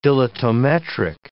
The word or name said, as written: dilatometric